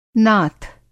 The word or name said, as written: नाथ